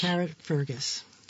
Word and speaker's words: Carrickfergus: Carrickfergus.